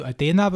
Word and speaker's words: Athena: Also Adena, würde ich sagen, ist jetzt sächsisch und für alle möchte gern UK-Briten oder wo auch immer, dann würde ich sagen Athena.